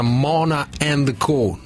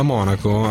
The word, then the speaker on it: Monaco: che c'è qualcuno che mi scrive: si pronuncia Mona and Co. No, si pronuncia Monaco. Allora, tra l'altro, a Monaco a fare il team manager c'è un ex collega che ha lavorato all'Inter, Andrea Butti, che ha commentato così questo sorteggio.